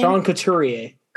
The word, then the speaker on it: Couturier: And again, always going to pronounce his name wrong, but Couturier, how you say his name? – Sean Couturier.